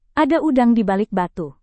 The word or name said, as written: ada udang di balik batu